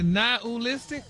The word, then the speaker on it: nihilistic: Nihilistic? – Wow. – Stop putting the ooh in there. – Nihilistic? – There you go.